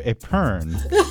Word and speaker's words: épergne: You could either have your baby carrots and broccoli on your lazy Susan, or you could have your crudité on your épergne.